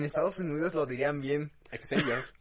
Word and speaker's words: Xavier: Aunque se pronuncia Xavier. Xavier. Xavier. Sí, como el de los Javier.